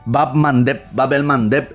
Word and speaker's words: Bab el Mandeb: Dominando el estrecho de el estrecho que yo le llamo de Etiopía porque me equivoco siempre con la pronunciación. Melamdet, creo que era el nombre de Etiopía, el estrecho que está allí. Les voy a decir el nombre correcto. Siempre me pasa.